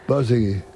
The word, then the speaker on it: Borzage: Dile cómo se dice Borzage. – Borsegui. – ¿Cómo? – Borsegui. – ¿Cómo Borsegui? Borsegui.